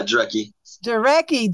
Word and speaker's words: Jarecki: I don't know if I'm pronouncing your last name right. – Uh, Duraki.